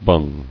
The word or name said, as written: Bunging